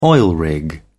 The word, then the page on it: oil rig: British English